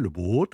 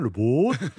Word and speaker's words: Le Boat: Le, faut le prononcer en français, boat en anglais, le boat. je le dis bien, le boat.